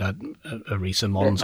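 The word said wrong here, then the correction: Arsia Mons: or whatever it is. – Valles Marineris and Arcea Mons.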